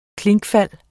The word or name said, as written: KLINKEFALDENES